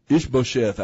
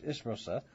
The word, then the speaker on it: Ishbosheth: Ishbosheth, I say. – Okay. Ishbosheth.